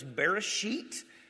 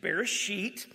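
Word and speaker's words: Bereshit (בראשית): Genesis, the word is Bereshit. It's not spelled that way. I didn't spell it the correct way because I didn't want you all giggling, but it is Bereshit.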